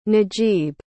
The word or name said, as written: najib